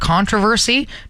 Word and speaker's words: controversy: He puts the emphasis on the tra instead of the ver, Controversy.